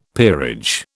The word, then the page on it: peerage: British English